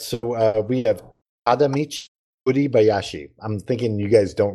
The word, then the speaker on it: Tadamichi Kuribayashi: Tadamichi Kuribayashi. – Well, at least I'm confident that you pronounced that perfectly.